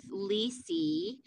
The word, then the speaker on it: Lisi: She got really creative and she went with Lisi. So it's like Lisa, but with an E sound at the end, but it's I. So it's L-I-S-I.